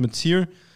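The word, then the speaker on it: Mateer: Spricht man ihn so aus? – Ja. Yep. – Oder? Hätte ich es auch gesagt. Metzier.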